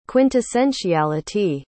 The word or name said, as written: quintessentiality